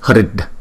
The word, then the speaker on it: Hrid: Chữ R ở dấu nặng này chúng ta đọc là R, R, R. Giống như là rồ máy xe đó, R. Nhưng mà chúng ta khi ráp lại đó là Hrid. Chữ D này đọc yếu lắm, Hrid, Hrid.